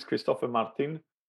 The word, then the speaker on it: Kristoffer Martin: I said it again in French, obviously. As you're going to hear, I do it in the interview as well, after directly asking how he pronounced it. But it's Kristoffer Martin, and he's actually brother of Kim Martin, the former Swedish goalie that got away with an Olympic silver from Torino.